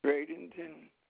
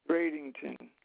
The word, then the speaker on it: Bradenton: You said Brady, Florida? – Bradenton. – B-R-A? – B-R-A. Excuse me, I'm going to spell it. – Yes, sir. – B-R-A-D-E-N-T-O-N. Bradenton.